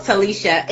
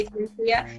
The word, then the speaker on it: Talishia: It's spelled Talicia, though, which is important. I always tell people it's important to say Talicia because they spell my name wrong. – Okay. – When they hear the Ia, they're like, oh, I-A. Oh. Yes, I-A.